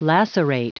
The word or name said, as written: lacerate